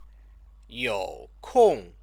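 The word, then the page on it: 有空: yŏu kòng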